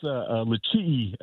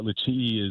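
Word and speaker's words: LeChee: Well, it's pronounced Lisee, Sean, but yeah, Lisee is a border town to Page, Arizona.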